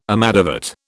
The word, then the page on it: amadavat: British English